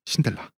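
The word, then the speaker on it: Sindelar: 마티아스 신델라. – 신델라? – 신델라. – 신데렐라 아니죠? – 원래는 체코계라서 이 성이 신델라를. 그러니까 독일식으로 발음은 신델라라고 해야 되는데 그냥 신델라라고 하겠습니다.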